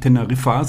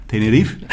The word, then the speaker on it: Tenerife: Und wir, die sagen ja auch nicht Teneriffa, sondern Tenerife.